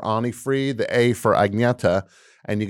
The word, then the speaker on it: Agnetha: Oh, is that how you pronounce her name? – Agneta? – I guess my dumb galoot of an American brain would always read it as Agnetha. – Yeah, it's Agneta.